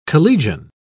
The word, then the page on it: collegian: British English